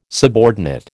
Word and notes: subordinate: American English

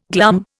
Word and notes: glum: Indian

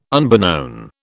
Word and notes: unbeknown: British English

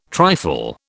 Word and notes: trifle: British English

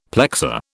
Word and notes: plexor: British English

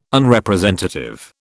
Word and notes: Unrepresentative: British English

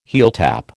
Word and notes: heeltap: American English

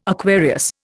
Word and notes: Aquarius: Indian

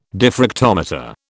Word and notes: diffractometer: British English

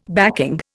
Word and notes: backing: Indian

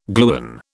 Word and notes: gluon: British English

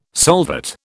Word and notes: solvate: British English

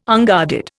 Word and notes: unguarded: Indian

